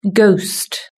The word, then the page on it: ghost: British English